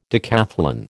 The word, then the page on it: decathlon: American English